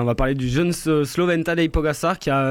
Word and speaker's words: Tadej Pogačar: On va parler de Vuelta, on va parler de vélo, et on va parler de Tadej Pogačar. Est-ce que c'est bien prononcé? – C'est parfait, vous êtes aussi bon en slovène qu'en anglais.